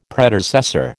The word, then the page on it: Predecessor: American English